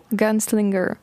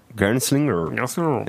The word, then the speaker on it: Gunslinger: Eh, tu prononceras ça comment Gunslinger. – Gunslinger. Et eh, j'avais raison.